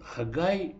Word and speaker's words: Хагай: Он Хагай, но из-за особенностей произношения он стал Агеем.